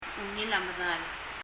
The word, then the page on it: ungil a medal: [ŋil ə məðal]